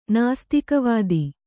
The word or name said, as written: ನಾಸ್ತಿಕವಾದಿ